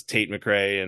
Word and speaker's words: Tate McRae: I hope that they do now, because Tate McRae and Justin Bieber and whoever else is.